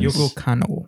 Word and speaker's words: Yoko Kanno: Yoko Kanno.